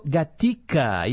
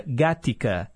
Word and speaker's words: Gatica: A gente ouviu Sabre Dios, e eu mencionei aqui, né, fiz erroneamente a pronúncia gática, e na verdade é gatica. Lúcio Gatica.